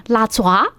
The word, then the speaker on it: 哪吒: 你知道四川念哪吒怎么念吗？ – 哪吒？ – 对，错。你这还算比较念，写都写不出来。我给你念一遍，拉爪。